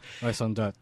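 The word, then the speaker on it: raison d'être: You build up that raison d'etre, or however you pronounce it in French. – Raison d'etre. Thank you.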